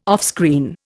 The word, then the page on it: offscreen: Indian